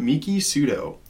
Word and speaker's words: Miki Sudo: And it was by Miki, I might be saying this wrong, but Miki Sudo. – That sounds about right to me.